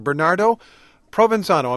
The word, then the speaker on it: Bernardo Provenzano: Bernardo Provenzano, am I saying his name correctly? The mafia boss of bosses has been arrested. – That's perfect the way you said it.